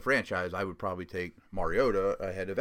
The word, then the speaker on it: Mariota: Mariota is right.